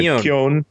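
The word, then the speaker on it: Keown: from Peter David and Dale – I never could pronounce this as a kid. – Kion.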